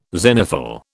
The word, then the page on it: zenithal: British English